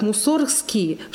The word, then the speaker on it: Мусоргский: Поэтому Мусоргский настаивал, например, на том, чтобы его фамилия произносилась как Мусоргский, чтобы было тоже корень Мусорга – муза, а не мусор.